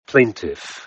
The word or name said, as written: plaintiff